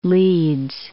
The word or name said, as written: Leeds